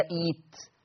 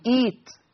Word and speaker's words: ائت: اما لو انا ابتديت بائت بقى، ائت، احنا لما بنتعلم حفص بنقول ائت ولا ايت، زي ائتوني بقول ائتوني ولا ايتوني، بقول ايت زي ما بقول ايتوني.